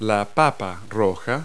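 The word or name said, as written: red potato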